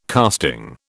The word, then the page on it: casting: British English